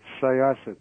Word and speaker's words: Syosset: It's Syosset because it's an Indian term, and you might be charged with some kind of hate speech.